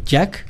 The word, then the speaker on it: JAC: Sí, vayan, dense una vuelta y bueno, pues obviamente aprovechen ahí las promociones que tiene esta marca Jack, que se escribe JAC, y bueno, pues acumulen estos Jack Coins que ellos le llaman, y bueno, pues a divertirse, ¿no?